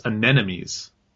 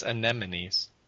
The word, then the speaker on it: anemones: Is it actually supposed to be anemones, or did they spell it wrong on purpose? I always thought it was anemones. – No, it's anemones. – Really? – Yeah, you've been saying it wrong.